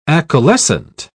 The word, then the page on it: acaulescent: British English